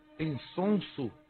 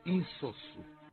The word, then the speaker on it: insosso: Qual a pronúncia correta? Insonso ou insosso? – A pronúncia correta é insosso. – Insonso ou insosso? – A pronúncia correta é insosso, porque vem do verbo insossar.